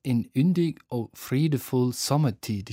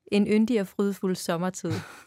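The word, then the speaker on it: I yndig og fredefuld sommertid: Das heißt In Yndig og Fridefuld Sommertid. Ich hoffe, ich habe das richtig ausgesprochen. – Meget fint. In Yndig og Fridefuld Sommertid. Sehr gut.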